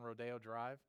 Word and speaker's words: Rodeo Drive: And then one of the chickens or goats got loose on Rodeo Drive. Is that in Beverly Hills? Did I say it right? I don't know. Is it? Okay, very good.